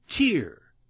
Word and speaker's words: tear: A drop of salty water which comes from the eye when one is crying, although this word is spelled the same as the word tear, which means to pull to pieces. It is pronounced quite differently.